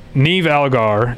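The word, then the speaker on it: Niamh Algar: I don't know if I really like, but Neve Algar, and yes, that's how you pronounce that.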